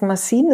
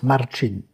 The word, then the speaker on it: Marcin: Habe gefunden, das heißt Massin. Ist das richtig ausgesprochen? – Nein, das heißt Marcin.